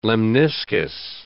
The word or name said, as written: lemniscus